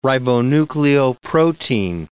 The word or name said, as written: ribonucleoprotein